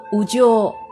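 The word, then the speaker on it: 烏城: 同样被称为鸟城，冈山城日语当中读作五鸠，而长野县的松本城读作卡拉斯鸠。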